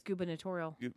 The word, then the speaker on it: gubernatorial: But this poor guy, the campaign manager for his gubernatorial race. – Is that how you say it? – Yeah, it's gubernatorial.